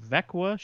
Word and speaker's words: Växjö: It's spelled Växjö, pronounced Vekwa.